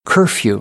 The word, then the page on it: curfew: American English; ɝː